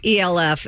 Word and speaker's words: ELF: Yes, ELF. It's E.L.F. But they call it Elf Cosmetics.